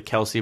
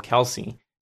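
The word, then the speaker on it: Kelce: Well, just to make it clear, people, the dad of the Kelsey brothers came into their podcast and said, you know, our last name is actually pronounced Kels. But he got tired of correcting people over and over and over again because people just call him Kelsey. So he just left it as Kelsey.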